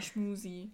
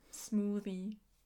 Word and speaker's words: Smoothie: Bei Schmusi muss ich übrigens immer an die Aussprache von älteren Leuten an, von Smoothie denken. Sage mal Schmusi. Stimmt. Smoothie.